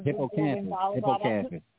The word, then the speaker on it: hippocampus: Hippocampus.